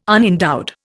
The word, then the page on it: unendowed: Indian